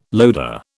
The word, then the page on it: loader: British English